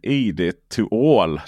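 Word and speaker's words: ID.2all: ID to all då ska ju det uttalas eller sägas.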